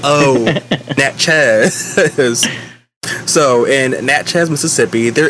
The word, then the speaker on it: Natchez: So now, and Nats, almost, I keep pronouncing it as Nazi. It's called Natchez.